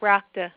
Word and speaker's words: rakta: The next tissue is called rakta, R-A-K-T-A.